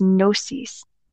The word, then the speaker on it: gnosis: Knowledge, this word is gnosis. And I think I said that right. I'm not sure. Gnosis is doctrine, knowledge, reason, and a plan. – I believe you said both of those words correctly. I believe.